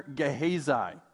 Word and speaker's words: Gehazi: Okay, new person here, Gehazi. That is how you say it.